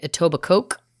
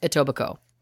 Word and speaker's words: Etobicoke: So it reads Etobicoke, but it is pronounced Etobicoke.